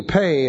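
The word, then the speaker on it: pe: This is P-E, pay is pronounced, and the letter actually looks like this in large.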